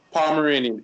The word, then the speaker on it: Pomeranian: Pomeranian. He said Pomeranian. – Pomeranian. – Like pom poms.